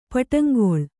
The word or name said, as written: ಪಟ್ಟಂಗೊಳ್